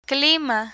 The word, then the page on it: clima: spanish